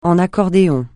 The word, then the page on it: en accordéon: francais